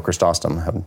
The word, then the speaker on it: Chrysostom: John Chrysostom uh, or Chrysostom, depending upon uh, how you pronounce his name.